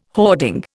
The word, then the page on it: hoarding: Indian